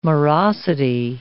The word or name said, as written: morosity